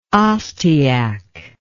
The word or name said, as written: ostiak